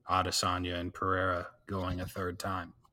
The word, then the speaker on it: Pereira: Pereira? – Oh, Pereira, yeah.